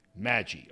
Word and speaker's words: Maggi: I heard Maggi. – Maggi. Okay, this is the story of Drew Maggi. And the video you're going to see is his manager at AA Altoona. And this young man, Maggi, has played.